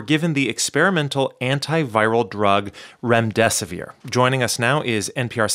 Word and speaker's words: remdesivir: Did I pronounce that drug correctly? – Remdesivir, yes.